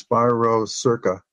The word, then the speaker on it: Spirocerca lupi: It's Spiro, S-P-I-R-O. And then it's C-E-R-C-A. That's the first word. And I pronounce this Spirocerca. Lupi. L-U-P-I. L-U-P-I.